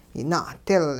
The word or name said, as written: yina'dil